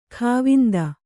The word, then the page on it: ಖಾವಂದ: khāvanda